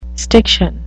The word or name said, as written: stiction